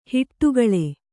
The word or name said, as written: ಹೊಡೆಗುಳು